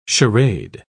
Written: charade

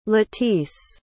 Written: latiece

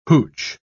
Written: hooch

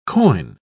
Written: coin

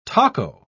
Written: taco

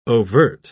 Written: overt

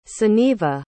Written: sunniva

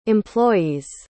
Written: employees

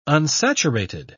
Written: unsaturated

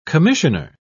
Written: commissioner

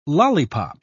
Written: lollipop

